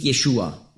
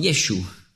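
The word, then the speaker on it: Jeschu (ישו): Aber sie sagen nicht Jeschua, sondern Jeschu. Also Jeschu schreibt man auf Hebräisch Jod, Shin, das ist bei uns SCH, und ein Vav, ein V. Das wird ausgesprochen als U, das V. Jeschu.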